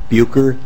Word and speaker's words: Bucher: My name is Clint Bucher. Last name is spelled B-U-C-H-E-R.